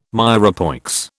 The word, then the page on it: mirepoix: British English